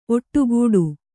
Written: ಒಡೆಗುಟ್ಟು

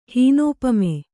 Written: ಹೀನೋಪಮೆ

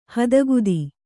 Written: ಹದಗುದಿ

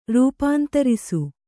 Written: ರೂಪಾಂತರಿಸು

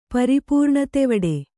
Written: ಪರಿಪೂರ್ಣತೆವಡೆ

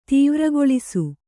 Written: ತೀವ್ರಗೊಳಿಸು

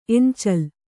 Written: ಎಂಚಲ್

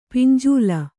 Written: ಪಿಂಜಲ

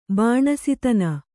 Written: ಬಾಣಸಿತನ

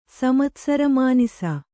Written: ಸಮತ್ಸರಮಾನಿಸ

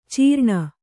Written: ಚೀರಣೆ

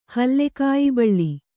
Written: ಹಲ್ಲೆಕಾಯಿಬಳ್ಳಿ

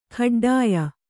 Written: ಖಡ್ಡಾಯ

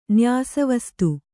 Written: ನ್ಯಾಸವಸ್ತು

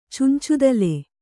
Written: ಚುಂಚುದಲೆ

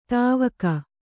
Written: ಧಾವಕ

